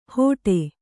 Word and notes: ಹೊಡೆ: kannada

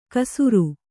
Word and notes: ಕಸುರು: kasuru

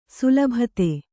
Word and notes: ಸುಲಭತೆ: sulabhate

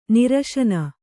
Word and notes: ನಿರಸನೆ: nirasane

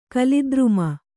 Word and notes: ಕಲಿದ್ರುಮ: kalidruma

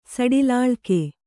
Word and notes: ಸಡಿಲಾಳ್ಕೆ: kannada

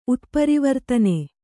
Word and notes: ಉತ್ಪರಿವರ್ತನೆ: utparivartane

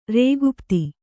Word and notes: ರೇಗುಪ್ತಿ: kannada

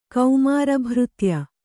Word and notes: ಕೌಮಾರಭೃತ್ಯ: Kannada

